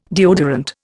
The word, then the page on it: deodorant: Indian